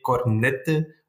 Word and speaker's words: Cornette: I don't know if there is really a why, but here I live in the west of Belgium, and here we say Matthias Cornette, but a lot of people say Cornette or Cornet because they pronounce not the T and the E at the end, but it's not a problem.